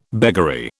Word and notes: beggary: British English

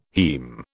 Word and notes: heme: British English

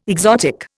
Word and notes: exotic: Indian

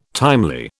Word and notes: timely: British English

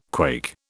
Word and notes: quake: British English

